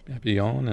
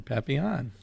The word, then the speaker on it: Papillon: Papillon, and Americans say Papillon.